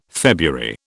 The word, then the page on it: February: British English